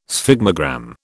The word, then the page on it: sphygmogram: British English